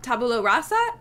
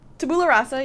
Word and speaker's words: Tabula Rasa: Today we have words to say about episodes 103 and 104 of Lost, Tabula Rasa, and Walkabout. Did I say it right? – Tabula Rasa, yeah.